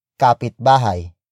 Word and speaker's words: kapitbahay: Which is the focus marker that introduces the second main noun of the sentence, which is kapitbahay. Kapitbahay. Kapitbahay. Kapitbahay is spelled as K-A-P.